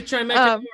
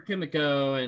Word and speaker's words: Chimecho: Either Chimecho or Chimico, and I think it's Chimecho. It looks like Chimecho.